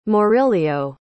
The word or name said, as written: Maurilio